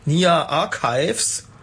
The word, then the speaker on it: Nia Archives: Ja, das war Vertical von Nia Archives. Habe ich das richtig ausgesprochen? – Hast du fein ausgesprochen. Richtig sehr gut.